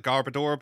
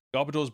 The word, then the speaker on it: Garbodor: I used to call it Garbodor, but no, it's Garbodor.